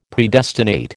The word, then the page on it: Predestinate: American English